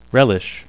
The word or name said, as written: relish